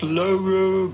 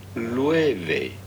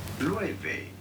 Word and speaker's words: Loewe: It's not Loro, but rather Lueve. Lueve.